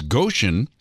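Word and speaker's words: Gotion: The company is Goshen, spelled like motion.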